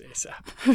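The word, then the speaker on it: A$AP Rocky: So how do you pronounce the dollar sign? – I think it's just ASAP.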